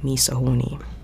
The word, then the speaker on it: MISOHAWNI: So it was spelled MISOHAWNI, which is supposed to phonetically sound like me so horny.